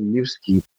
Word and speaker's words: Lil Skib: My stage name is actually Lil Skib, which is spelled as L-I-L-S-K-I-B.